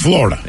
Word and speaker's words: Florida: Rather than focus on the hard R sound in Florida. So it's actually Florida. – Florida. – Flour instead of floor.